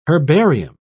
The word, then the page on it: herbarium: British English